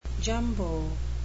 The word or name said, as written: jumbo